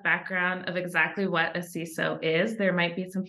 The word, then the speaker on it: CISO: We pronounce it CISO, other people pronounce that acronym in other ways.